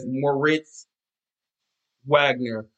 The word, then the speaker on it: Moritz Wagner: Yeah, let me try to say his name again. His name is, Moritz Wagner.